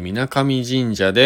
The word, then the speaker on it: 皆神神社: 今回はねえっ、ー、と長野市の松代長野県松代にあるね、えー、水上神社、皆、皆神様と書いて水上神社で行われているパワースポットにね、出店して、最後のね、ライブ見てきました。